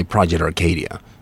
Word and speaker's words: Project Arcadia: Well, I say Project Arcadia, mm-hmm. but that's because, you know, I'm from Sweden and I live in the US and I speak English more than I speak Bulgarian.